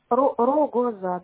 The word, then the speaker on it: Рогоза: Анастасия Игоревна, фамилия Рогоза. – А через О пишется, да? Ру-го-за. – Рогоза, да. О, там везде О.